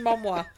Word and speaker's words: Momoa: Momoa? I mean, if it's pronounced Samoa, he's definitely right. Jason Momoa.